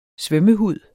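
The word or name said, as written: SVØMMEHUD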